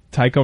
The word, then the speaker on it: Tycho: What type of music is Tycho? Because I'm only seeing the... remote control cars here. – It's more like... – how do you spell it? – T-Y-C-H-O. – Oh, silent H. That's what it is.